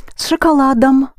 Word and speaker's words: шоколадом: С шоколадом. Yes, it has ом, but it's not accented, so it sounds like ам.